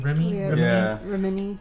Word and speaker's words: Remini: It's Leah Remini's, is that how you say it? – Remini. – Scientology Remini.